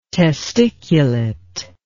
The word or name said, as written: testiculate